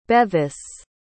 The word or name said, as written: Bevis